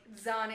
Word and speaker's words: Zahn: As I think someone pronounced in the chat one day, it's like Zahn is pronounced like Thrawn.